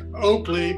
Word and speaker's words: Oaklea: Well, Oakley, but it looks like Oakley. Like okay. L-E-Y instead of L-E-A-Y.